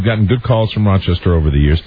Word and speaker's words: Rochester: You don't pronounce the S in it. It's Rochester.